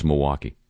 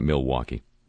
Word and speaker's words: Milwaukee: You go to Milwaukee, and it's Milwaukee. It's not Milwaukee. And similarly, here, it's Toronto. It's not Toronto.